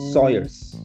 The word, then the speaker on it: Sawyers: É o Brentford, pelo que eu, o, a última notícia que eu vi do Brentford foi que o Sayers, né? Eu não sei como pronunciar o nome do meio-campista lá que saiu, que foi justamente pro Crash Brown. Crash Brown. – Ah, o, Sawyers. O meia.